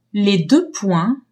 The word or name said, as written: deux-points